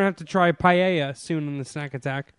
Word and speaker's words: paella: 16, putting chorizo and hard-boiled eggs in paella. – Paella. Here, paella. Thank you for cleaning that up.